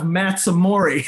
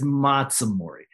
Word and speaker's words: Matsumori: But when I got to Hawaii, and first of all, I was told that I was pronouncing my name wrong. I was calling myself Matt Samori. I remember the first person I introduced myself, and he goes, no, it's Matsumori. That's a Japanese pronunciation.